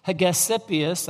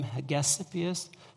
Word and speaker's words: Hegesippus: Hegesippus, I guess is how you pronounce his name, Hegesippus,